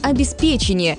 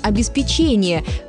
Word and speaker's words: обеспечение: Что говорят словари? Большинство из них утверждают правильный вариант «обеспечение». Правда, в начале нашего века появились несколько словарей, допускающих вариант «обеспечение», как разговорный и даже как равноправный.